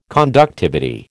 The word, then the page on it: conductivity: American English